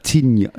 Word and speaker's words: Zwiertnia: Nee, er heißt Marc, und ich kann das nicht aussprechen, ne, aber Zwiertnia, also C mit dem Strich, W-I-E-R-T-N-I-A. – Wie? Der heißt gar nicht so wie er. – Zwiertnia.